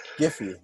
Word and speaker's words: Giphy: Giphy.